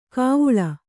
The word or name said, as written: ಖಾವುಳ